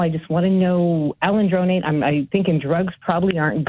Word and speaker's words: alendronate: The doctor wants to put me on alendronate.